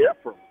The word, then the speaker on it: Ephraim: Yeah, people make it more complicated. I just say Ephraim.